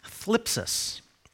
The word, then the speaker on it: thlipsis: In fact, the first word, tribulation, it's thlipsis. Sounds like I'm speaking with a lisp, but that's just the way it's pronounced.